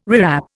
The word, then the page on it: rewrap: Indian